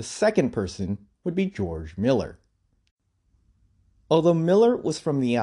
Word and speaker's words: Mueller: His last name is actually spelled like Mueller, as in Robert Mueller, but apparently he pronounced his last name as Miller.